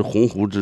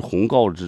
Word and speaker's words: 鸿鹄: 那人光听搁那闲扯西游，那你听我的干啥呀，是不是，你不能犯这样错误啊，对不对，你还有那个你比如说你那个北大原来那个校长嘛，燕雀焉知鸿鹄之志，他给念成个燕雀焉知鸿告之志，